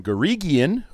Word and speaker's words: Guregian: And this week, it's Boston Herald legend Karen Garigian, who I just learned it's a hard G.